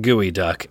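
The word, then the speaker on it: geoduck: It spells G-E-O duck, but it's pronounced gooey duck.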